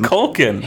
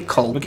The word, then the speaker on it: Culkin: I can't even pronounce his last name on its own. – Culkin. – Hey, Culkin. – Macaulay Culkin. – Culkin. – Culkin. It's like you're culking the yeah, the roof.